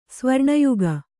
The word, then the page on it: ಸ್ವರ್ಣಯುಗ: Kannada